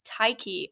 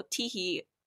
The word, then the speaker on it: Tyche: Okay, so I'm going to say this in the English pronunciation, but I know Greek people out there are going to kill me for this. But I research the Greek goddess Tyche, or Tihi.